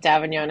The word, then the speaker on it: d'Avignon: Angela D'Avignon is fine.